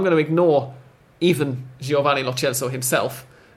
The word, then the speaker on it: Lo Celso: Apparently, when asked about it on the radio earlier, but they had another person on who said that UEFA's own pronunciation guide a couple of years ago suggested Lo Celso for it,